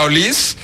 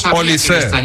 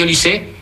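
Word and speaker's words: Olise: Oye, el otro día tuve una agria polémica con los compañeros porque tú me garantizaste que se pronuncia Olís y me pusieron cortes del propio jugador que dijo que es Olisé. – No, efectivamente se dice Olisé,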